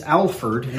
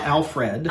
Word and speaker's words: Alferd: And it's spelled A-L-F-E-R-D. Spell check doesn't like it, but that is actually, it's Alfred, not Alfred.